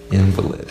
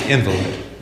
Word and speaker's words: invalid: All these, by the way, the common English pronunciation is invalid. Invalid.